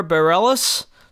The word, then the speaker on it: Bareilles: I want to start, before I get too deep in this, by saying for years I had pronounced her name Sarah Bareilles. I thought that was correct. I have since heard several people who should know say Sarah Bareilles, so I'm trying to get used to saying that, and I'm sorry if I'm saying it wrong, but I think that that's correct after years of thinking otherwise.